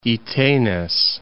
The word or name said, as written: Itenez